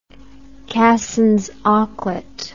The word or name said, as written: cassins auklet